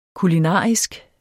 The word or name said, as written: kulinarisk